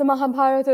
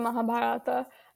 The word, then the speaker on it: Mahabharata: Yeah, the Mahabharata or the Mahabharata. Whether you pronounce the A at the end just depends on region.